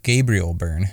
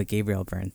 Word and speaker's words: Gabriel Byrne: Gabriel Byrne. – Oh, Gabriel Byrne.